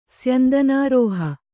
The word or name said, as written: ಸ್ಯಂದನಾರೋಹ